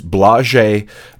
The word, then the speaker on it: Blażej: And I actually went and consulted one of my Polish friends to ask how would I properly pronounce his first name. So hopefully I got this right. It's Blażej Malinowski with Madness.